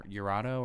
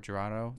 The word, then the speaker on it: Jurado: It's called Ever Since by Damien Jurado or Jurado. It's a J, but I don't think it's pronounced with a J.